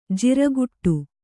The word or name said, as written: ಜಾರುಗಾಡಿ